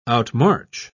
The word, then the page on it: outmarch: British English